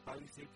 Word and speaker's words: Palisade: Se escribe P-A-L-I-S-A-D-E, pero se pronuncia Palisade porque pues es un nombre,